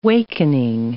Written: wakening

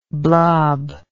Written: blobby

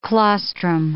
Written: claustrum

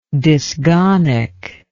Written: dysgonic

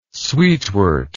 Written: sweetwort

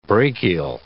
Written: brachial

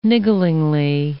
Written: nigglingly

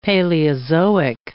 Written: Paleozoic

